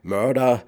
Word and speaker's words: Merda: Aus Köln geht es in die USA zur Soul-Rock-Band aus Detroit, Michigan, Black Murder, geschrieben M-E-R-D-A, ausgesprochen in Englisch wie Mörder, also in Deutsch Mord.